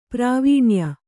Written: ಪ್ರಾವೀಣ್ಯ

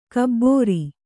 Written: ಖಬರ

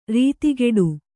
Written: ರತಿಗೆಡು

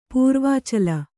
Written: ಪೂರ್ವಾಚಲ

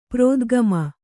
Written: ಪ್ರೋದ್ಗಮ

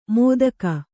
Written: ಮೋದಕ

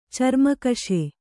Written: ಚರ್ಮಾಕ್ಷಿ

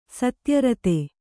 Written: ಸತ್ಯರತ

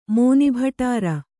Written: ಮೋನಿಭಟಾರ

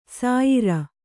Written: ಸಾಯರ

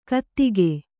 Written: ಕಾದಗ

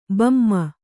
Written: ಬೊಮ್ಮಿ

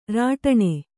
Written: ರಟನ

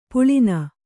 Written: ಪಳ್ಳನೆ

